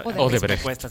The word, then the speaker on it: Odebrecht: Sí, y en estos casos uno no tiene que quedarse en la persona jurídica de que van a haber contratos necesariamente con Odebrecht. ¿Cómo se dice? – Odebrecht.